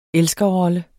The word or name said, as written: elskerrolle